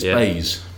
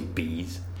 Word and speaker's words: BEIS: How do you say it? – Apparently, it's BEIS.